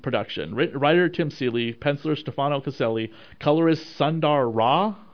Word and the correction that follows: Sundar Raj: if I'm pronouncing that correctly. – Sundar Raj.